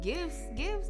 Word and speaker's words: GIF: The gifts. Is it a hard G or a soft G? – It's a hard G.